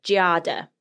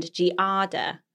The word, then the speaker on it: Giada: So Italians pronounce Giada as a two syllable with the accent on the first, which is Giada. – Okay, Giada. So I said it really wrong, right? – So, and then Americans tend to use the three syllables, how you say it, with the emphasis on the second, Giada.